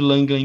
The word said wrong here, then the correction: Largent: em, 1988. Largent, corrigindo aqui a, a pronúncia, em 1988 é isso, cara.